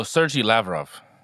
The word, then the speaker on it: Sergey Lavrov: I think it's like Sergio Sergio Lavarino. I don't know. that's so Sergey Lavrov. – There you go. See, he got that man.